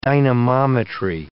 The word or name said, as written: dynamometry